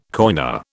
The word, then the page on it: coiner: British English